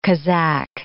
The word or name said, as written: Kazak